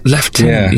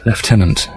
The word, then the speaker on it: Lieutenant: And there was some pronunciations that always confused the shit out of me, and one of them was Lieutenant. – Lieutenant, yeah. yes. Lieutenant.